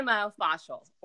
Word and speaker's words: myofascial: Well, I think that leads us into the first thing I wanted to ask you, which is you've been teaching Pilates or myofascial release. Is that how you pronounce that? Myofascial? – I say myofascial. – Myofascial. – Or you can get rid of the myo even and just say fascial release.